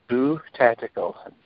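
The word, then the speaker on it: Bu Tactical: My business name is Boo Tactical. B-U is how it's spelled.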